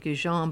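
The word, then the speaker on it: Gijón: It's, I pronounce it Gijon, but it's, the G is supposed to be soft.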